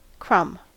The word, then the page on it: crumb: American English